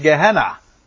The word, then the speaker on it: Gehenna: Als je dat op zijn Grieks uitspreekt, dan zeg ik Gehenna. Dus Gehenna is gewoon de Griekse uitspraak van een Hebreeuws woord.